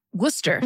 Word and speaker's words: Worcester: What, how do you say it? – Worcester. – Worcester, that's it.